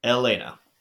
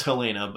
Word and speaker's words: Elena: yeah, so I I just thought that I thought uh was it did you say Elena? – Elena. – Oh wow, we've been saying it wrong all this time. – I know, I've been saying it wrong too. I admit that. I thought it was Helena, but it is Elena.